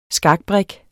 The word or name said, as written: skakbrik